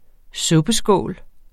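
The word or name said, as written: suppeskåle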